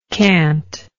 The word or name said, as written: cantic